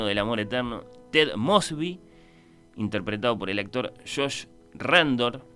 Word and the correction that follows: Radnor: lo dije mal, es Josh Radnor, le cuenta en un extensísimo flashback, ¿no?